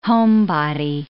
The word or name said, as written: homebody